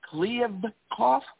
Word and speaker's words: Kliavkoff: The Pac-12 has hired a commissioner, George Kliavkoff.